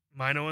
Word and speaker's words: Mino: This guest, I'm not going to say his name, but it rhymes with Mongino. – Nice. – That's such a bad... It's John Mino.